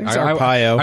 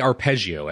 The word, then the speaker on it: Arpaio: I can never say his name right. – Arpaio. – I want to say arpeggio every time I see it, but that must be the music background.